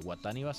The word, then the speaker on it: Wataniba: Guataniba se escribe W-A-T-A-N-I-B-A. – B alta A.